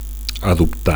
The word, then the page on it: adoptar: Catalan